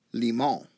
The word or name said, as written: limon